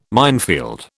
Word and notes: minefield: British English

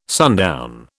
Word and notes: sundown: British English

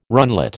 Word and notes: runlet: American English

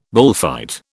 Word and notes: bullfight: British English

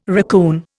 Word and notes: racoon: Indian

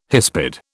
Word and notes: hispid: British English

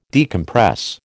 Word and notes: decompress: American English

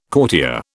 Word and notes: courtier: British English

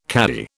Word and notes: cadi: British English

